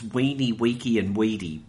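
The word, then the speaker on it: Veni, vidi, vici: Of course, I think the Romans didn't pronounce the V, so in actual fact it's weeny, weeky and weedy, but never mind.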